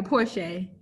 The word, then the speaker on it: Porche: And for our audience listening again, her name is Stacy, S-T-A-C-Y. And I don't know if I pronounced it right, but the last name is P-O-R-C-H-E. – Yeah, Porsche. Yeah. – Porsche. Yeah. Then when I saw that and that you were in Louisiana, and I said, oh, well. And then when you said your grandparents from Louisiana, I said, okay, I know this is pronounced differently.